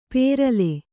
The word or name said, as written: ಪೇರಲೆ